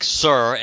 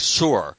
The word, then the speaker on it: Sur: because some people say it like Sir, as in S-I-R, and some people say it more like Sur, almost like a sewer, but not quite that pronounced, but like Sur.